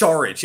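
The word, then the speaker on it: Šarić: It's Saric. Yeah, you pronounce his last name with a sh. Yeah, with an SH, even though it's just S.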